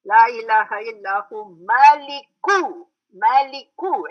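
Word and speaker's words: Maliku (مالك): Everybody pronounces Maliki because they have seen Malik in other parts. Maliki Yawm Middin in the first part. So here also they go Maliki. It's not Maliki here, it's Maliku Yawmiddin.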